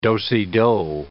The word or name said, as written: do-si-do